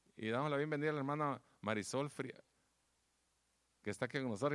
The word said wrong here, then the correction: Farias: Iba a decir el apellido, pero no lo puedo pronunciar bien. ¿Frías? Farias. Farias, dijo. Hermana Marisol Farias, sea bienvenida a nuestro servicio de español.